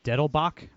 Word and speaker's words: Dettelbach: So this week, Biden's pick to head the ATF, Steve Dettelbach, I believe it's pronounced, was confirmed, I believe, yesterday, 48-46, every Democrat plus Republicans, Rob Portman and Susan Collins.